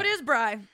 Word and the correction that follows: Bri: It is Bri? It is pronounced Bree. It's the next line down. I wanted to follow up to let you know. It is Bree.